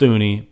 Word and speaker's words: Thuney: It's spelled Thuney, but it's Thuney, as I've been told.